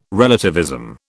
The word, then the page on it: relativism: British English